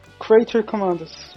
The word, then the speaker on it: Creature Commandos: Creator Commandos, é assim mesmo que se pronuncia, gente? Creature Commandos.